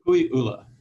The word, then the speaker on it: Kukui'ula: Kukui'ula.